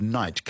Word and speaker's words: knight: And then some words we borrowed and simplified, like the word knight, K-N-I-G-H-T, which is a perfectly good English word. And it used to be Knicht. Pronouncing the K, and with the G-H as a H, Knecht.